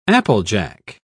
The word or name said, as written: applejack